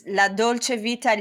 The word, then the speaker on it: La Dolce Vita: So you are currently the founder of La Dolce Vita. – Yes. – Did I say that right? – This is La Dolce Vita Leadership and Lifestyle Consulting.